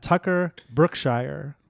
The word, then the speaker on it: Tucker Brookshire: uh, very funny comedian, uh, Tucker Brookshire.